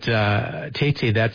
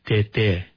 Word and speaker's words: Tay-Tay: But Tay-Tay, it means grandfather in the Tewa language, but it's not spelled correctly. It's not pronounced correctly. – Right. – It would actually be pronounced Tete, right? – Yes, you're yeah.